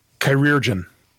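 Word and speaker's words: chirurgeon: Chirurgian. Or chirurgian. It's actually an archaic word. It's an archaic version of the word surgeon.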